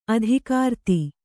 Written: ಅಧಿಕೃತ

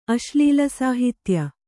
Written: ಅಶ್ಲೀಲಸಾಹಿತ್ಯ

